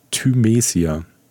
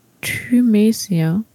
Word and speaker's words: Thymesia: Thymesia. Thymesia. Ähm, Hyper ist zu viel. Thymesia. Hm. Ty, ty, ty. Woher kommt denn diese zweite Silbe? Äh, dritte. Also Hyper ist zu viel und dann kommt Thymesia.